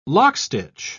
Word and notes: lockstitch: British English

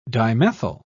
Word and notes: dimethyl: British English

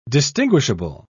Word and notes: distinguishable: British English

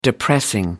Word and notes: depressing: American English

